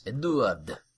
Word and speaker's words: Edouard: Edward Mass, or Eduard Mass. – Eduard. – And, it's spelled E-D-O-U-A-R-D.